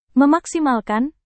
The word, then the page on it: memaksimalkan: Indonesian